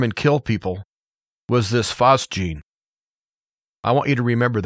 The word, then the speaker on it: phosgene: It's spelled P-H-O-S-G-E-N-E. Phosgene is how I'm going to pronounce it.